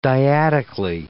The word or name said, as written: dyadically